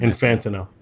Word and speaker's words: Infantino: And I just couldn't pronounce Infantino for some reason. – Infantino.